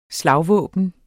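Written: VÆBNET

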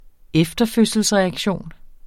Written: efterfødselsreaktion

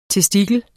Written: testikel

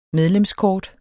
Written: medlemskort

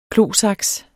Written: klosaks

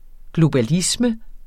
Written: globalisme

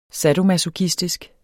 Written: sadomasochistisk

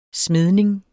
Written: smedning

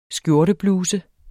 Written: skjortebluse

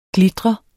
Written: glitrende

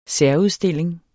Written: særudstilling

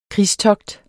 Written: krigstogt

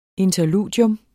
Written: interludium

